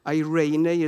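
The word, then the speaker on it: eirene (εἰρήνη): Well, first of all, this particular word here in the Greek, eirene is the pronunciation, and it talks about the Messiah's peace.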